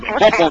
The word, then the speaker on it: Popo: Sí, Popo, con acento en la O primera, no en la segunda.